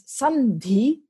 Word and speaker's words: sandhi: And samdhi becomes sandhi because an N is easier to pronounce in front of a dha. They're both pronounced with your tongue against your teeth.